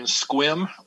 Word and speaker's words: Sequim: We are in Squim, Washington. It's spelled S-E-Q-U-I-M.